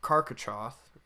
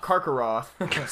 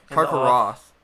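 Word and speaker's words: Carcharoth: And outside of the gates, they encounter the werewolf Karkaroth. – Karkaroth. – Sorry, I said that wrong. It's very close. I got the Karka at the start. – Yeah. – Karkaroth.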